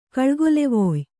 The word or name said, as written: ಕಳ್ಗೊಲೆವೊಯ್